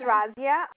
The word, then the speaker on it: Razia: It's Razia, R-A-Z-I-A. – You said R-A-Z-I-A? – Yeah.